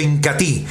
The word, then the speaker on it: Incatea: Producto líder de la aldea Ramazón, el Incati, se escribe Incatea, pero se pronuncia Incati,